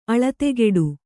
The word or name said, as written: ಆಳತಿಗೂಡು